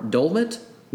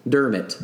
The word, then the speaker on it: Dermot: I was like, is it an L or an R? Dolmet? Dermot. No. Dermot.